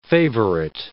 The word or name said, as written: favourite